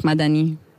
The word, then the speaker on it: Madani: Bei Madani ist es, glaube ich, durchaus auch aus der Originalschrift oder Schreibweise übertragen worden und soll eigentlich auch helfen, den Namen auf der letzten Silbe zu betonen. – Genau, also es ist nicht in der Schreibweise so, es wird mit einem i geschrieben, okay, aber die Betonung ist einfach anders bei Farsi. Das wird einfach Madani.